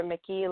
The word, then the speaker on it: Muecke: That's Kendra Muecke. Last name is spelled M-U-E-C-K-E.